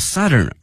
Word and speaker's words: southern: Hangzhou is both the southern end of Beijing Hangzhou Grand Canal，京杭大运河，杭州是它的这个最南端了，用了这个 end E-N-D，同时啊前面用这个 southern 而不用这个 south 啊，用它的这个南部的南端的形容词，同时这个发音哈，大家已经注意到了是 Southern 小口型的啊音，而不是这个 South 啊大口型的这个 ao，